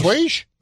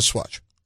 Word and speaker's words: assuage: It's pronounced assuage. – There you go. – Not swatch.